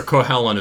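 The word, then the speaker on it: Cú Chulainn: Cuchulainn, depends. For somebody who's half Irish, I should know my Gaelic pronunciation better. Cuchulainn is how we usually say it in the U.S.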